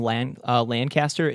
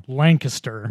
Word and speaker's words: Lancaster: Lancaster. – Lancaster, did it? Really? – Oh, yeah. That's definitely correct. It's not... Lancaster is in Buffalo. Lancaster is in Pennsylvania. – Wow. Wow. – That's a weird pronunciation.